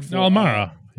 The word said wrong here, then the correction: Kamara: Uh, Kamara. – My fault. Yeah. Golly, forgive me. They they have Kamara. I think that's how you say it too.